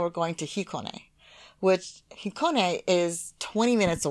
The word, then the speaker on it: Hikone: So Hikone, Hakone, and Heikone are all cities in Japan. So H-I is Hikone, Hakone is H-A, and Heikone is H-E.